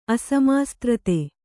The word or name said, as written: ಅಸಮಾಸ್ತ್ರತೆ